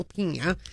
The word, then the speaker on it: pigna: One of the days you were not here, Jay and I had this whole conversation about La Pina. – It's spelled P-I-G-N-A. – And it's pronounced like this. – Pine cone. – Pina. La Pina. It's Italian. – Yes. – G-N is like nya.